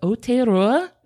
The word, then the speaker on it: Aotearoa: It is me and Christian here with our very good buddy, Thomas Realstone from the History of Aotearoa.